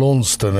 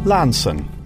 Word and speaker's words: Launceston: And Launceston, as you hear all too often, absolutely wrong. Even the BBC Dictionary of Pronunciation gives its correct pronunciation, and that is Lanson. – Lanson.